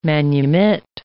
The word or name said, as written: manumit